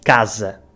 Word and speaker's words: casa: Portanto, dizemos casa, porque o S no meio de duas vogais fica Z, casa.